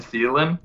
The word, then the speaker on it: Thielen: And DJ, Adam Thielen? – Yeah, Adam Thielen, that guy.